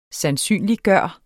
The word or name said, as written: sandsynliggøre